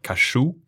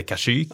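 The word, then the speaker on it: Kashyyyk: Eh, I eh, specialen så uttalar de det Kashuk. Jag har alltid trott att det hette Kashuk.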